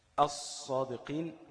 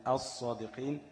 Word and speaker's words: الصادقين: طيب، الصادقين، أص مش ال، الصادقين، الرحمن، الرحمن، وهكذا في باقي الحروف.